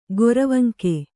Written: ಗೊರವಂಕ

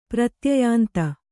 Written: ಪ್ರತ್ಯಯಾಂತ

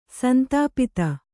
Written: ಸಂತಪ್ತ

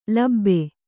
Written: ಲೋಭ

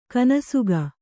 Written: ಕೊನೆಸಾಗು

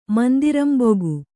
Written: ಮಂದಿರಂಬೊಗು

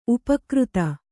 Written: ಉಪಾಕೃತ

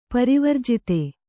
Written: ಪರಿವರ್ಜಿತ

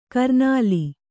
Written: ಕರ್ನಲ್ಲು

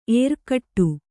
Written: ಎರಗಾಡು

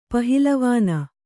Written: ಪಹಿಲವಾನ

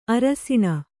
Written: ಅರಸಿಣ

